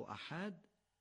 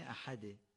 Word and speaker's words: احد: ما در فارسی میگیم احد، اما در نماز باید بگیم احد. باید حی رو یه مقدار غلیظ از گلو بگیم. اگر حی رو یه مقدار غلیظ از ته گلو بگیم، خود به خود درست تلفظ میشه. بنابراین چی بگیم؟ احد یا احد؟ قطعا دومی درسته.